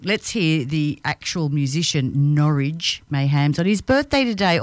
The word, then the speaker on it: Norwich: I should have spelled Norwich because it's not Norwich. It's Norwich rhymes with porridge.